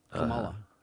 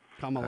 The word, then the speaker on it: Kamala: So you got this Kamala Harris is how you're supposed to pronounce it. Kamala. Kamala.